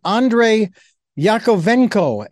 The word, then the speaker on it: Andrei Yakovenko: And it is a book as well as a program by Philip Shepherd and Andrei Yakovenko.